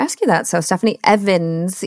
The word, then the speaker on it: Ewens: It looks like Ewens. It's Stephanie Ewens. But it's pronounced Evans. It's weird. I know. – it is pronounced Evans. Okay.